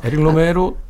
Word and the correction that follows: 에릭 로메르: 아, 그 에릭 로메르. – 아, 에릭 로메르. 네. – 에릭 네. – 로메로라고 제가 잘못 아, 네. – 발음했는데 아, 네.